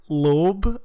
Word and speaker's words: Loeb: Remember when I tried to pronounce Loeb as like Lieb? – I'm dyslexic.